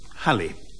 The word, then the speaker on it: Halle: Halle.